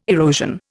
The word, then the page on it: erosion: Indian